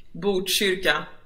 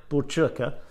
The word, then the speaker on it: Botkyrka: And in fact, in the city of, uh, forgive me if I'm pronouncing it wrong, Bruskirka? – Botkyrka. – Botkyrka.